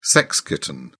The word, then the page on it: sex kitten: British English